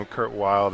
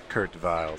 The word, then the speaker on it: Kurt Weill: We're calling him Kurt Weill. That's the American pronunciation, and then the European pronunciation is Kurt Weill.